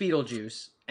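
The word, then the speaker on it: Betelgeuse: So it is pronounced Beetlejuice, and he is named after a star that is in the constellation Orion.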